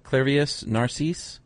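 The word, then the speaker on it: Clairvius Narcisse: How do you pronounce that? Clavius Narcisse? – Yeah, that's how I pronounce it.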